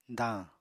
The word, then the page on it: dañ: apatani